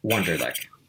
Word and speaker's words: Wunderlich: There is a phrase in German, and it does the German pronunciation of my last name, which is Wunderlich. So you will hear that here. Wunderlich.